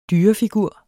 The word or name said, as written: dyrefigur